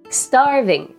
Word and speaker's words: starving: starving 意味は腹ペコ。アクセントは A につくので starving となります。